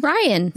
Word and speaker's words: Rian: Our newbies are Rianne. I hope I'm saying that right. It's R-I-A-N. Is that Rianne? Ryan? Rianne? – Ryan. – Is it Ryan? – R-I-A-N? – Yeah.